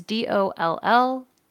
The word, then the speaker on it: Dollanganger: So on July 13th, 2019, Canadian singer Nicole Dollinger, I am very bad with pronunciation. It's Dollanganger.